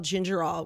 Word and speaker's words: gingerol: Is it spelled like ginger ale? – No, it's without the I. – It's like ginger ol.